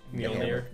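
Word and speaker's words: Mjölnir: Yeah, the running gag of just Stormbreaker and I can't pronounce Mjolnir.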